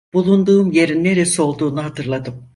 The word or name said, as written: neresi